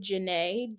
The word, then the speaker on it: Janai: It's Jade Janae. Janae is spelled J-A-N-A-I. My parents are bougie. – So that's your middle name. – Yep, Jade Janae.